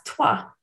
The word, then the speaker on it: Troyes: T-R-O-Y-E-S, Troyes, yeah. – And that's toi. – And that's actually pronounced in French, toi, as in, like, I guess, number, toi.